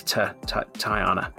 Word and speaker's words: Tiana: Tiana? Not a like, you don't say th? – No, it's Tiana.